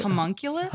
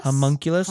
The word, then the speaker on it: homunculus: This humongous, is that how you say it? – Homunculus? – Homunculus.